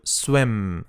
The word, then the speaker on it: swim: Importante que no es can, can you, que no es you, you swim. Swim, la I como una e.